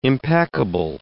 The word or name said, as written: impeccable